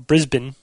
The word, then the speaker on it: Brisbane: This one comes from Sean Dowling from Brisbane, Australia. You're not supposed to pronounce the A or the E, apparently. Brisbane.